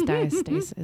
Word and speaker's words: diastasis: It's diastasis. Is this diastasis? – Oh, yeah.